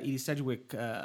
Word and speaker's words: Sedgwick: the Edie Sedgwick, am I saying it right? – Yeah. – Edie Sedgwick story I thought was like pretty cool.